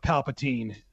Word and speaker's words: Palpatine: Abrams, for his part, says in a new interview with Empire, some people feel like we shouldn't revisit the idea of Palpatine. Did I say it right that time? – Yes.